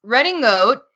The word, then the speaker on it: Redingote: It's Running Goat, and that's spelled REDINGOTE. So just so you know.